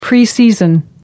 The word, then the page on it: preseason: American English